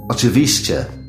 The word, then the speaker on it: Oczywiście: Oczywiście. Chodźmy. Szybko. Ooh, that's awfully difficult to pronounce. Try it. – Oczywiście.